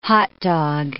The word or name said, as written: hotdog